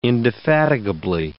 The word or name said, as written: indefatigably